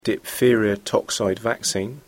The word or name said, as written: diphtheria toxoid vaccine